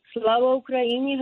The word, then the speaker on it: Slava Ukraini: Slava Ukraini, Heroev Slava.